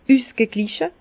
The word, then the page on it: üsgegliche: Bas-Rhin; Herrlisheim